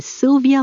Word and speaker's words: Silvia: Hello, my name is Silvia Lopez. It's a very simple name to pronounce, but when you see it written, you will notice that Silvia is written with an I instead of a Y.